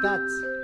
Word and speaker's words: that's: No es das, es dats.